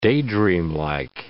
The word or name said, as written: daydreamlike